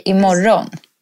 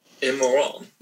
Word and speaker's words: imorgon: Idag is today, but then tomorrow you say imorgon. – Oh, imorgon. – Mm? Great pronunciation. – Like good morning. – Say it again. Yeah, almost. – Imorgon.